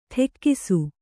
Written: ಟಕ್ಕಿಸು

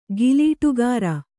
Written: ಗಿಲೀಟುಗಾರ

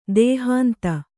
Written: ದೇಹಾಂತ

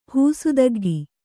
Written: ಹೂಸುದಗ್ಗಿ

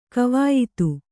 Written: ಕವಾಯಿತು

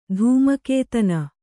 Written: ಧೂಮಕೇತನ

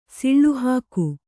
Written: ಸಿಳ್ಳೆಹಾಕು

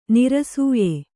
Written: ನೈರಸ್ಯ

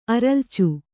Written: ಅರಲ್ಚು

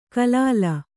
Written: ಖಲಲು

